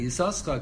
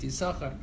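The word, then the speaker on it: Yisoschar (יששכר): The reason why we pronounce it Bnei Yisoschar, because that's the way he pronounced it by Kriyas HaTorah.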